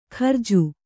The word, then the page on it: ಖರ್ಜು: kharju